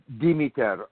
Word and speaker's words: Demeter: The accent is on the first. We say it Demeter, and that's how that usually comes out.